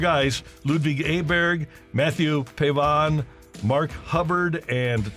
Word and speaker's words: Ludvig Åberg: Ludvig Åberg.